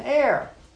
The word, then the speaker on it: heir: So the person who gets all these things from their deceased loved one is called an heir. H-E-I-R, heir. H is silent.